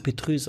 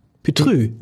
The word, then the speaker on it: Petrus: Doch, wir hatten doch Das Petrus. wird auch falsch ausgesprochen übrigens. – Das Petrus. Leute Petrus sagen. – Petrü.